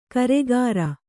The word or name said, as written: ಕರಿಕಾರೆ